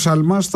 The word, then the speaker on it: Σαλμά: Να πούμε πολύ γρήγορα και για την άρση ασυλίας του κυρίου Σιαλμά, έτσι δεν είναι. – Σαλμά, συγγνώμη για το γιώτα που εμφυλοχώρησε. Στην προφορά μου.